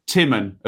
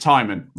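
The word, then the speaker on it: Tymon: Josh Timman of Stoke. Supposed to pronounce it Timon, right? Definitely Timon now.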